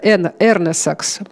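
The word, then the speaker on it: Эрнесакс: Создатель государственного гимна Эстонии, дирижер и композитор Густав Эрнесекс, вот правильно, да, Эрнесекс, по-моему, такое ударение, был 5 годами моложе.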